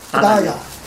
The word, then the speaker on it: 佛陀耶: 再一个，咋样听这个不达呀，他都不像是佛陀耶这三个字，那就是这三个字的读音，不达呀，他就读着不达呀，就是写出来就是佛陀耶。